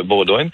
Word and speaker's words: Bowdoin: Alors, oui, ça s'écrit, disons, B-O-W-D-O-I-N. On pourrait le prononcer Baudouin, effectivement, mais j'ai l'impression que les gens là-bas, euh, en tout cas de ce que j'entends, le prononcent euh, Baudouin.